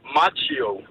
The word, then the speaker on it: Macchio: Så ham der, Ralph Karate Kid, hvordan udtaler man navnet? – Macchio. – Så det er Macchio, ligesom at være Macchio? – Ja, Macchio.